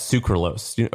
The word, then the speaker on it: sucralose: So I eat them, but a lot of people don't like it because it has sucralose. Or is that how you pronounce it? Sucralose? – Yeah, that's right.